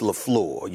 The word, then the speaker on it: LaFleur: And we don't want to mispronounce the name. It's LaFleur, you know.